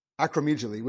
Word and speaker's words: acromegaly: Acromegaly, which is basically, it makes everything bigger, like your forehead, the jaw, the nose.